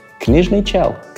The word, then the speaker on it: Книжный чел: Книжный чел.